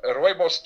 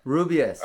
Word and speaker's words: rooibos: Rooibos tea, what you might call red bush. – Rubius. – Because it's too difficult to pronounce in the English language. It's actually referred to as rooibos.